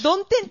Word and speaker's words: 曇天: これ今までこうどん天って読むって読み方今日初めて知りました、